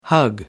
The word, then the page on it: hug: American English